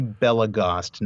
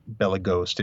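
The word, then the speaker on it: Belegost: So as I just said, it's actually Belagost, not Belagost. It rhymes with crossed, not toast.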